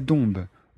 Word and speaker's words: Dombes: À l'ouest, il y a les monts du Lyonnais et à l'est et au nord, il y a la plaine de la Dombe. Dombe s'écrit D-O-M-B-E-S et l'Isère.